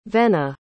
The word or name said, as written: vennie